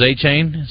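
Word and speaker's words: Achane: Got the dude from the Dolphins, A-Chain.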